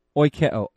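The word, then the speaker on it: Oikeo (οἰκέω): Oikeo. O-I-K-E long O. I guess is how we would transliterate it. Oikeo.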